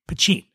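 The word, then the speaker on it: Poitín: And when I say Poteen, it's spelled P-O-I-T-I-N, Poteen.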